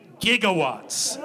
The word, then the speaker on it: gigawatts: 1.21 gigawatts. Gigawatts. We pronounce this with a hard G because we are not monsters. All right? You soft G people should be ashamed of yourself.